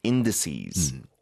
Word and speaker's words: indices: Major chip producers like Samsung Electronics and SK Hynix now hold massive collective weight within Korea's benchmark indices.